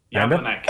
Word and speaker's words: Jablonec: Jablonec.